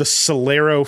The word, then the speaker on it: Celero: It's called the Celero 5G. It's spelled with a C. Imagine the word celery and then put an O on the end.